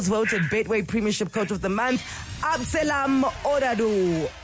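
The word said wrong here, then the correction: Abdeslam Ouaddou: Oadu. Am I saying that right? Abdeslam Oadu. – That's it.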